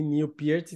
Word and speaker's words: Neil Peart: Eu falo Neil Peart, Neil Peart porque até onde eu sei, o nome dele se pronuncia assim Neil Peart,